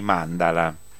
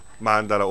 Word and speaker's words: mandala: Mandala o mandala, sì. Sono rappresentazioni. – Forse mandala. – Mandala. – Sì, mandala. – Sì, mandala. Lui è stato clemente nei miei confronti. Io ho sbagliato l'accento.